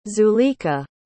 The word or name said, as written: zuleikha